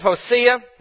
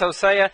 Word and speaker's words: Hosea: He said Hosea is Jewish. And so that is the why I pronounce it that way.